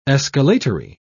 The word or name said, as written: escalatory